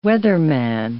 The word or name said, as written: weatherman